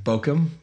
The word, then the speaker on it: Bochim: I remember somebody said that the CH is followed by an I, you usually make a hard sound. – Okay, so Bochim, so it's pronounced Bochim. So the phonetic is B-O-K-I-M.